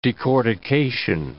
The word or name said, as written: decortication